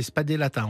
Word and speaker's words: spadellata: Donc ça aurait donné spadatella. Hein, c'est spadellata en fait.